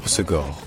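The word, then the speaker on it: Hossegor: Dans l'accent du sud-ouest, donc localement, on prononce ça en trois syllabes. On dit Osegor. Osegor. Osegor.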